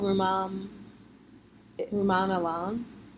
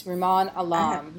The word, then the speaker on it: Rumaan Alam: How do you pronounce the woman, the author's name? I actually think it's a man. Like Rumaan Anam or something like that? – Oh, Rumaan Alam? – Yes, Rumaan Alam, yeah.